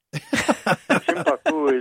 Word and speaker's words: Shimpaku: And I'm looking at a picture on my website right now of a Shimpaku juniper bonsai. Did I pronounce that correctly? – Yeah, you actually pronounced it the Japanese way. Shimpaku is Japanese. Americans, we tend to call it Shimpaku.